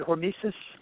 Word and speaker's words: hormesis: Did I say that right? Is it hormesis effect? – It is. That's exactly how you say it.